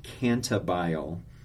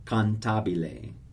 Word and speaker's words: cantabile: Some people pronounce this cantabile. No, don't do that. It's four syllables. Cantabile, cantabile.